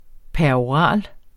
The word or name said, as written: peroral